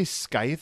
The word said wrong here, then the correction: scythe: What's a Skythe? – That's a scythe, sorry.